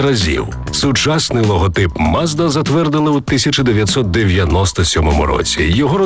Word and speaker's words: Mazda: Назва також походить від імені засновника компанії Мацуда, яке японською вимовляється як Mazda.